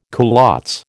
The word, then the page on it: culottes: American English